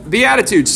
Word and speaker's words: Beatitudes: Beatitudes.